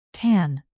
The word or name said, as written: tan